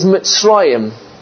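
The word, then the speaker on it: Mitzrayim (מצרים): The name for Egypt is Mitzrayim. Mitzrayim is spelled Mem, Sadi, Resh, Yud, Mem.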